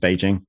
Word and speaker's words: Beijing: There are various arcane reasons why all these things get spelt in different ways, why Peking got spelt with a K, for example. And in fact, it's pronounced Beijing.